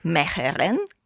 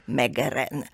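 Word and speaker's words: Meegeren: I tę sztukę opanował tylko jeden człowiek na świecie, również Holender, Meheren, albo częściej on jest znany, bo wymawia się jego nazwisko nieprawidłowo z punktu widzenia języka holenderskiego czy niderlandzkiego, Meheren.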